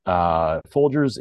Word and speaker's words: Folgers: Do you say pronounce it Folgers? – Yes, Folgers. It was a major coffee brand, especially through the 90s.